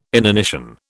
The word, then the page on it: inanition: British English